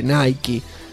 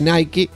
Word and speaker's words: Nike: Francisco Jamier Zamudio González dice: muy bueno el programa, me hicieron reír mucho, excelente, pero déjalo que pronuncie Nike. – que está correcto. Vamos, el chingo anglosajón tosquero todavía. Y por si alguien quiere decir que está mal pronunciado así, dejo esto. Y dejo un link de YouTube, de la publicidad de Nike, de las zapatillas de Volver al Futuro. Y sí, ahí dice Nike.